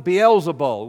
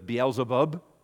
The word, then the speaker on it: Beelzebul: He is following Beelzebul. We sometimes refer to it as Beelzebub, which is pronounced that way sometimes.